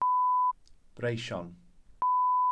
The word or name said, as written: breision